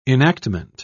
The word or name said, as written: enactment